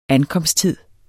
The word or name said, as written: ankomsttid